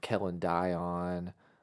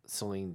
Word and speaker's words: Celine: I was sitting there, I just got my new laptop, watching probably some Whitney Houston, watching a little bit of maybe Kellen Dion, that's Celine Dion, for people who don't know how to pronounce the name, but they're only looking at it and reading it.